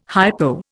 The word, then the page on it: hypo: Indian